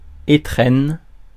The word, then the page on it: étrennes: [e.tʁɛn]; [de.ze.tʁɛn]